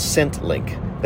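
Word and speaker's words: ScentLinq: called ScentLink. That's S-C-E-N-T, like scent, and then :, link, but it's link is spelled L-I-N-Q.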